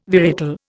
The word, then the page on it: varietal: Indian